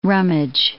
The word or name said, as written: rummage